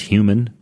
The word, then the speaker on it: HVMN: So the company HVMN, pronounced human, they make a good ketone ester.